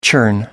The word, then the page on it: churn: American English